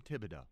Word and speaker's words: Thibodeau: I think his name is Tom Thibodeau. – It is. The H is silent.